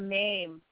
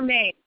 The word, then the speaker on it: meme: We need a meme. Is that how you say it? – Yeah. – Is it meme or mame? – Either one.